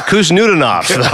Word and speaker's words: Khusnutdinov: The last one I'm going to go with here, Marat, M-A-R-A-T. That's the first name. Last name now, K-H-U-S-N-U-T-D-I-N-O-V. So K-H-U-S-N-U-T-D-I-N-O-V. – Marat Kostanov. – Marat Kuznudinov. – Kuznudinov. I missed a whole syllable in there.